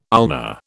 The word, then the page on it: ulna: British English